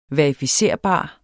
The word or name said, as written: verificerbar